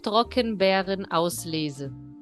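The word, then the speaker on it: Trockenbeerenauslese: TBA é Trockenbeeren Auslese.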